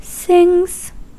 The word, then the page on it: sings: American English; [sɪŋz]